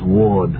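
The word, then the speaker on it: Werde: No, it's W-E-R-D-E, but it's pronounced Ward.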